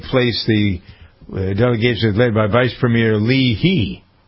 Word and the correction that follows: Liu He: Is that how you pronounce that? Li He? Louis? Louis He. Liu He.